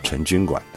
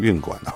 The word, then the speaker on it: 成均馆: 那这个均呢，那还有一说叫做运哦，呃，这个我们说的这个押韵的韵啊，成均啊，成均馆。当然你念成运馆哦，哎，不知道的人你不知道哎，还是念的成均馆啊。